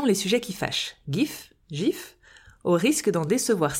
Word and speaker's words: GIF: Tout comme le dictionnaire anglais Oxford qui accepte les deux prononciations, mais, et je cite, à tort, c'est un G doux, prononcé GIF.